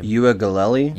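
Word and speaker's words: Uiagalelei: Uagalelei.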